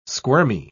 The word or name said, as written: squirmy